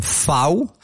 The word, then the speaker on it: fau: C'est de lui qu'est issu le terme occitan fau, prononcé faux en français.